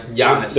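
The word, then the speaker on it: Giannis Antetokounmpo: Giannis. – There you go. Antetokounmpo.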